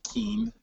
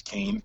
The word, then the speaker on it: Kean: So it's off more often than not do people say Keene, and it's Kane, K-A-N-E, the pronunciation, but it's spelled K-E-A-N.